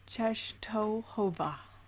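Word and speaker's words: Częstochowa: It is the Black Madonna of Czestochowa. – Okay. Beautiful pronunciation.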